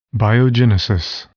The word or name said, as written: biogenesis